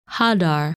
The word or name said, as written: Hadar